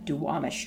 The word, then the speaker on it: Duwamish: And it was the traditional territory of Coast Salish, which is specifically the Suquamish and the Duwamish tribes.